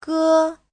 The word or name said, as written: ge1